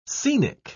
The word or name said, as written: scenic